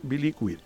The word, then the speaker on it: Billiquid: Por hacer la deferencia de venir a contarnos un poco el proyecto como socio fundador de Billiquid. ¿Se dice así bien? – Sí. Nosotros decimos Billiquid.